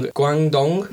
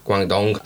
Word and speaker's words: Guangdong: Guangdong, it comes from. – You know what the sad thing is? Guangdong is a place, and I think he pronounced it right.